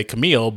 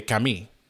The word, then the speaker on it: Camille: But in America, we'd say Camille, but in France, they say Camille.